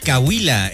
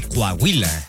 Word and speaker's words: Coahuila: Yo conocí a alguien que decía Cahuila en vez de Coahuila.